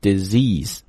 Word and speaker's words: disease: more than 400,000 deaths annually，在这里这个 disease 指代的就是前面那个 malaria，注意一下这个单词的读法这个单词呢实际是一个很好的你来练语音的单词，因为 di 发的是非常短音的这个 d 然后第二个音节这个 C's 是个长音，所以是一个短音的 A 一个长音的 E，所以读的时候应该读成 disease， OK， one more time， disease 这么一读就好听了对吧，很多同学读的是 disease 不好听，或者 disease 也不好听， disease 非常悦耳 The disease causes 会导致 more than 400,000 deaths annually annually 表示每年，每年呢将会造成这个 40 万的这样的一个死亡的人数。